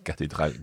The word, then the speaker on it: cathédrale: And watch the H after the in cathédrale and in gothique, because obviously in English you would say cathedral and gothic. Okay, but in French it's silent.